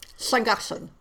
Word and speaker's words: Llangollen: the ladies of Llangollen. – Sangassen.